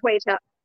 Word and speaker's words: Shweta: Shweta.